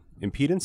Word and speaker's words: impedance: and it's measures the electrical impedance. Is that how you say it, Chad? – Yep. That's right.